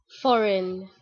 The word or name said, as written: Foreign